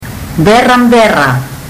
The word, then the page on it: berabera: fiji